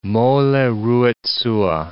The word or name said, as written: mole ruit sua